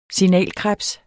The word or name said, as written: signalkrebs